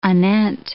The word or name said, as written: anent